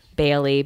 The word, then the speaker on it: Bailey: Bailey, BAILEY, and it's the same thing on Twitter, @KaytlinBailey.